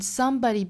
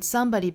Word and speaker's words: somebody: z 就很像有滋有味的 z，这个 z 的声母，所以 bends, bends, then somebody bends, then somebody bends. Somebody 这个词重读是在第一个音节啊，所以读起来不是 somebody，应该是 somebody。